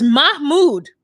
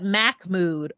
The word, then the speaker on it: Mahmoud: It's Mahmoud. Mahmoud. Or as the gays call him, Miss Mood. Anything but Mac Mood or Mock Mood. There's no C or K in it.